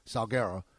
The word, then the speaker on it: Salguero: Now, last week, a very respected Miami writer, Armando Salguero, I think is the way you pronounce his last name, Salguero, reported that the Jets were Watson's first choice.